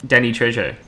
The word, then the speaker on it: Trejo: Danny Trejo.